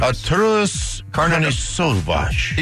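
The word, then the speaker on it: Artūras Karnišovas: Masai Ujiri, and then they lost Arturas Karnišovas. – Yeah, that guy. – Arturas Karnišovas. – Oh, it's Karnišovas. Oh, it was always Karnišovas. – Arturas Karnišovas.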